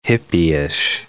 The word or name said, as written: hippieish